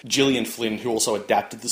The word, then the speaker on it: Gillian: I believe she's one in a fast-growing group of hard G Gillians.